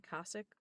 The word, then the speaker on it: Cossack: And so much of what you see in that movie is historically accurate, including, you know, when the Cossack soldiers, did I say that correctly? Cossack. Yeah.